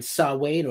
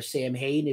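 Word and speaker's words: Samhain: He's in Danzig's other band, Sawade, or Sam Hain, if you don't pronounce Gaelic words correctly.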